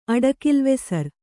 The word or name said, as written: ಅಡಕಿಲ್ವೆಸರ್